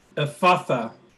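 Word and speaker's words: Ephphatha: the word Jesus uses, as Grace just so perfectly pronounced, is Ephatha.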